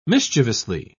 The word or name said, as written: mischievously